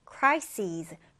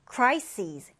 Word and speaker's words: crises: 우리 복수형으로는 이렇게 es로 끝에 써주는데, 이때는 발음이요. crises, crises. 이렇게 끝에를 z 발음해주시면 돼요.